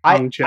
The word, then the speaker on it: Hong Chau: Is that how I pronounce it? – Hong Chau? I believe so.